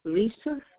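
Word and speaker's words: Lisa Phoenix: Lisa, L-I-S-A, Phoenix, P-H-E-A-N-I-S.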